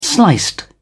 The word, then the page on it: sliced: British English